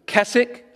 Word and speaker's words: Keswick: It comes out of a little town known as Keswick, spelled with a silent W.